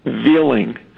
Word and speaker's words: Veiling: His last name is spelled V-E-I-L-I-N-G, Veeling.